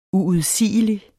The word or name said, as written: uudsigelig